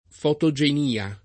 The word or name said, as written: fotogenia